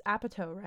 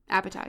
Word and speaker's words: Apatow: There was a documentary in 2014, Judd, it's Apatow, right? – Apatow, yeah.